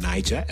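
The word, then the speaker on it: Naija: But anyway, the second sponsor segment will be used to promote a great new documentary called Niger Better, which is spelled N-A-I-J-A-B-E-T-A. Niger as in Nigerian.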